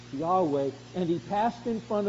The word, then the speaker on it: Yahweh: God has revealed his personal name to his people, and his personal name is pronounced something like Yahweh, as it's written here with small capitals, Lord.